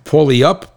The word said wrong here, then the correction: Puyallup: Polyup? – Oh, Puyallup? – Puyallup. Oh, Pew, like Pew, it stinks. Puyallup.